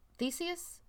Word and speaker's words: Theseus: Ship of Theseus, which I have talked to... Theseus? I said that right.